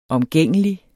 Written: omgængelig

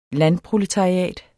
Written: landproletariat

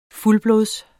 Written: fuldblods

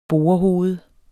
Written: borehoved

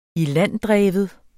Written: ilanddreven

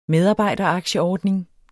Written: medarbejderaktieordning

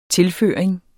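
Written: TILFØRINGERNES

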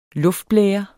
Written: luftblære

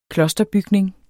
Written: klosterbygning

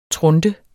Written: trunte

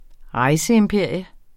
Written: rejseimperium

